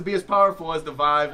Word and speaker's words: Vive: Is it Vive or Vive? – Everyone else pronounces it as Vive, so I'm gonna go with Vive.